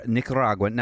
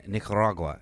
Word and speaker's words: Nicaragua: Don't go Nicaragua. Don't also go Nicaragua. – Yeah, say it in English. If you're in English, stick to English.